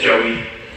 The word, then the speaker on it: Joey: There's I had a friend from Sri Lanka who always called me Joy. My name is Joey. That's how you pronounce my name.